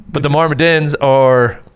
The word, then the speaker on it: Myrmidons: And it's the Myrmidons. I just don't know if I said that right. – Myrmidons. – Yeah. Pretty fucking close.